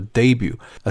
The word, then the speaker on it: debut: 这个词呢来自于法语啊，注意那个 T 字母呢是不发音的啊，所以读作 debut，a special feature 啊，它发布了一款这个特殊的功能啊，叫做在家上课啊，或者叫 homeschooling 啊。